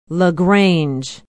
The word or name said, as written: la grange